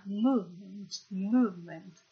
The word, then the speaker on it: movement: Ah, movement. Questa pronuncia di movement richiede di nuovo di sporgere, di fare una U sostanza. Movement. È come se fosse, ecco, questa è un po' una U francese. Movement.